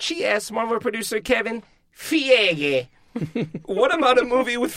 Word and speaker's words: Feige: Am I pronouncing that right? – Feige. – See, I was going with you, Alonzo. – Let's never pronounce it right. Let's just never pronounce it right. – Thank you. – Feige. – I like that very much so. – Fig. – She asked Kevin Feige, what about a movie with female superheroes? – No, it really is Feige.